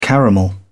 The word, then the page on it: caramel: British English